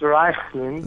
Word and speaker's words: Raichlin: It's pronounced Raichlin.